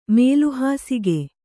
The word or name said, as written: ಮೇಲುಹಾಸಿಗೆ